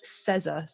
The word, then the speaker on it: Sezza: um, and my social media handle uh, on Instagram, Facebook, uh, and Twitter is at Coach Sezza.